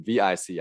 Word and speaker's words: VICI: VICI, right? – VICI, yeah.